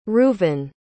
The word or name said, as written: rouvin